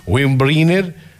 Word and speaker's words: Windbreaker: Bata Bubblegummers, Windbreaker, está bien dicho, está bien pronunciado, Windbreaker, Skechers, Adidas y muchas más.